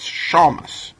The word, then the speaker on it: shamus: And they have a pronunciation guide, and according to Merriam-Webster, it's Shamus.